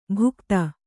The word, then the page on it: ಭೂಗತ: kannada